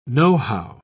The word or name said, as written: nohow